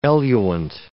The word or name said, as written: eluent